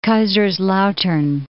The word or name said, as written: Kaiserslautern